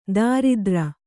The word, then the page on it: ದರಿದ್ರ: daridra